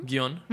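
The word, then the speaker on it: Gion: It's called Pass the Baton in Kyoto, in Gijon. Am I pronouncing that correctly? – Gijon.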